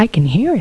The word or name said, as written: I hear it